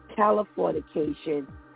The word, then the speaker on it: Californication: So, everybody's heard of pretty much Scrubs and, you know, some of the other NBC shows like California. I always say this wrong. Californication.